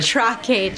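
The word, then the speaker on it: Trà Quế: The trake chai. – Trake chai. – T-R-A. Space Q-U-E.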